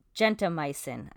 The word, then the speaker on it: gentamicin: Is it A, penicillin, B, streptomycin, C, amoxicillin, or D, gentamicin? I might be mispronouncing that one. – No, you said it correct.